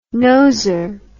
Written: noser

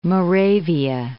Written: Moravia

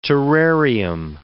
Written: terrarium

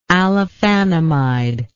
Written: allophanamide

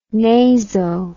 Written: naso

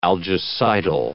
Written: algicidal

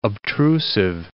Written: obtrusive

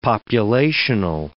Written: populational

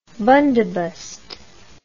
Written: bundobust

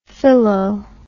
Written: Philo

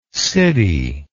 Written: siddhi

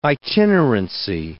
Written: itinerancy

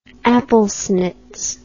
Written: applesnits

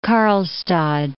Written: Karlstad

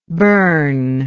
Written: Berne

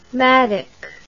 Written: madoc

